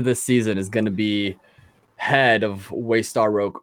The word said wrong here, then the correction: Waystar Royco: Oh my gosh, did I just say that wrong? Waystar Royco. Yeah, who do you think is going to be CEO end of the season? – No one that we've seen so far.